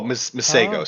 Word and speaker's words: Masego: Yeah, actually, Catherine is just Masigno now. Oh, Masego, sorry, I'm mispronouncing that.